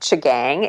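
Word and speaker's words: M'Chigeeng: They're in a community which is pronounced something like Chigang. It's actually spelled with an M. – Okay. – M apostrophe C-H-I-G-E-E-N-G. And the M is silent.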